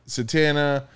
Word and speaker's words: Zatanna: Zatanna.